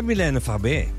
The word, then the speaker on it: Mylène Farmer: Of, nou ja, dat zal dan ook wel op zijn Frans moeten, toch? Mylène Farmer waarschijnlijk. Hoe zou jij het uitspreken? – Ja, Mylène Farmer. – Ja, hè? – Dat is het ook.